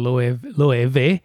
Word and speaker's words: Loewe: You know, he's always taking chances, and with what he wears, a lot of Loewe, as we learned. That's the way you pronounce it.